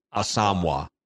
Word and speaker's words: Asamoah: That he apparently went on a Vikings Entertainment Network video and said it's pronounced something closer to Asamoah or Asamoah.